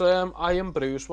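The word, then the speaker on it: Irn-Bru: That's Iron Brew. – I'm keeping this in. I'm keeping a lot of this in. I'm learning a lot in this one. – Also, Iron Brew is the superhero name of Iron Man's coffee machine, Java.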